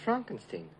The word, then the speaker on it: Frankenstein: No, it's pronounced Frankenstein. – Do you also say Frederick? – No, Frederick. – Well, why isn't it Frederick Frankenstein? – It isn't, it's Frederick Frankenstein.